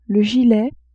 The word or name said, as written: gilet